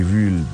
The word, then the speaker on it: Viy: Autre truc, ça, c'est probablement celui que j'ai préféré jusque, ben, en fait, de ce que j'ai vu depuis la semaine dernière, qui est un film qui porte le titre de... Bon, je trouve plus. Voilà. La légende de V. C'est un film V s'écrit V-I-Y.